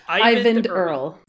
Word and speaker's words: Eyvind Earle: And one of the most important people on this movie was Eyvind Earle. – Eyvind Earle. At least that's how they say it on the bonus features on the movies discs.